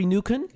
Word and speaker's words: Noeken: Do I pronounce that right? Jeffrey Nuken? – Ja, hallo.